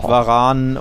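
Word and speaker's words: Varane: Varan und endlich ja.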